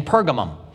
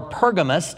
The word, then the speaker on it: Pergamum: And I think to some degree, we will find ourselves in Pergamum. Pergamum or Pergamos, depending on what translation you're using or who you're listening to, both of them are ways that the city is pronounced, is the compromising church.